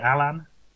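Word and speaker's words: Allan: I mean, Alan, is that how you pronounce his name? Is it Alan? – Yep, he's not Irish, so you got that fine.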